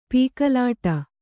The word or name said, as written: ಪೀಕಲಾಟ